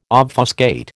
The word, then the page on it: obfuscate: American English